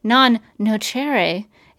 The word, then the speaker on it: Non Nocere: Meanwhile, as Lena and Hope work to launch Project Non Nocere, is how Katie McGrath pronounces it.